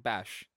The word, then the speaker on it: Beige: Let's hear how this is pronounced. – That's what Vader was really yelling when he told him that Padme had passed. Beige. – Bash. Bash.